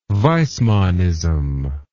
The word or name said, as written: weismannism